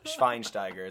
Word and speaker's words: Schweinsteiger: It's Schweinsteiger. It's not. – I'm not even gonna attempt it again. – Okay, well, it's really fun to say. So if you're gonna say it right, and it's German, Schwein, not swine. – Schwein. – We're not talking about pigs. We're talking about Schwein.